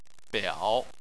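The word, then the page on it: biao: tone 3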